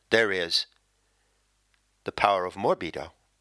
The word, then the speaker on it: morbido: And I guess the way to do that is to give you the seven-letter word, which, it so happens, rhymes with libido. And that word is morbido, M-O-R-B-I-D-O.